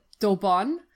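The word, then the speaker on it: d'Eaubonne: French feminist Françoise, I'm terrible with French, Daubon, there, because there's an E at the end, so I would pronounce the N, Daubon, has been credited with coining the word ecofeminism in 1974 in her book Feminism or Death, which the translation is from 1980.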